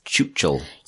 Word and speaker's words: Chuchel: Chuchul. – Yeah. – Am I pronouncing that right? – Yes.